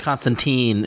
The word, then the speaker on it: Constantine: It's pronounced Constantine in the DCU. – No, it's not. – In the DCU, it is. – In Vertigo, it's Constantine. That's how we'll differentiate.